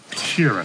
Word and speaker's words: Sheeran: Frank Sheeran. Am I saying that right? – Yeah, you said right.